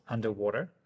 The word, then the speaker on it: UNDW3: So I think their project here that they've launched, which we'll take a look at now, UNDW3, which is pronounced underwater. – Underwear? – Underwater. – Underwater? I'll take your word for it.